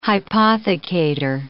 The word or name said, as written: hypothecator